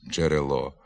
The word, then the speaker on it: джерело: На місці букв «дж» та «дз» вимовляється не один звук, а два. Наприклад, «джерело» замість «джерело», «кукурудза» замість «кукурудза».